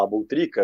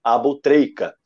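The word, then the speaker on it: Aboutrika: Aliás, eu vou parar de falar Abutrica, eu comecei falando Abutrica para a galera lembrar e vou passar a falar da forma correta, como os egípcios pronunciam, Abutreica.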